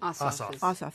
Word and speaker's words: Ossoff: I mean, Ossoff is... Is that how you pronounce it? – Ossoff. – Thank you. Just want to make sure I had that right.